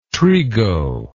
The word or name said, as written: trigo